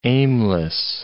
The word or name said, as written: aimless